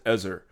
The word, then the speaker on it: Ezer: It is an awful translation of the word Ezer or Ezer. I've heard it pronounced both ways. I'm going to pronounce it Ezer.